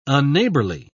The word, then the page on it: unneighborly: British English